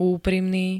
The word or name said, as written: úprimný